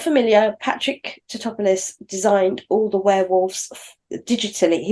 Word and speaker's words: Tatopoulos: Speaking to Patrick Tatopoulos, but they did, and I'm sure that's how you say his name. So if it isn't, it is Patrick Tatopoulos.